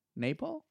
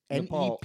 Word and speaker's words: Nepal: Napal? Napal. – Yeah, you're saying it like napalm, which it's not. It's Nepal.